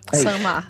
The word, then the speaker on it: Saint-Mars: Eu vou ler aqui então, a pronúncia correta. Ó. – Ah. – Benigné Delvarne de Saint-Mars. De Saint-Mars. – Saint-Mars. – Perfeito.